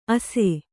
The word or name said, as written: ಅಸೆ